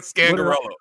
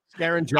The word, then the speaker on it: Scangarello: Didn't even say his name right. Scangarello. – Is that it? – Yeah, Scangarello. – What did I say? Scarangelo? – I don't know.